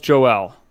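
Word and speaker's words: Joël: It's Joel, technically. – Joel, okay. – It's the French pronunciation. There is an accent on the E.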